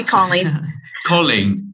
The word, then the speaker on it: Colleen: Thank you, call me Colleen.